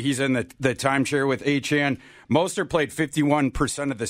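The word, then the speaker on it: Achane: I'm going to give both Mostert and Achan, as we've learned, is the pronunciation.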